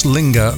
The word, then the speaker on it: Linger: Thank heavens Linger rhymes with finger.